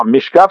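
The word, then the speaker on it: Michkov: J'étais sûrement mal prononcé encore une fois, mais je pense mm. qu'il pourrait jouer pour les Russes l'année prochaine. Non, Mishkov, c'est correct. Le H est avant le K.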